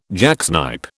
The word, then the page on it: jacksnipe: British English